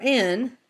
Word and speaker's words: Ain: And our 45th entry is Ain, or An. It's An. It's it's spelled like Ain, like aim, with an M. But this is N as in Nancy.